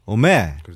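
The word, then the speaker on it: 오메 (おめえ): 보통 뭐 야쿠자들은 오, 오 원래는 오마인데 음. – 그렇게 발음은 안 하죠. – 음. 오메라고 그렇죠. 발음을 하죠.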